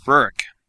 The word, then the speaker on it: Rurik: Rurik. R-U-R-I-K.